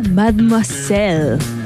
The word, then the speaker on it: Mademoiselle: Mira, se llama Mademoiselle. ¿Lo dije bien? Sí, me gusta.